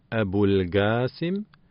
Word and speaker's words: Abul Qasim: Hier sei zu beachten, dass das S im Namen Abul Qasim scharf wie das SZ im Wort Straße ausgesprochen wird.